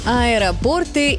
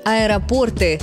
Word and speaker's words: аэропорты: Аэропорты и города. Наверняка многие сейчас мысленно продолжили эту строчку. И заодно поставили ударение. А куда? Правильный вариант – аэропорты с ударением на «о», не аэропорт.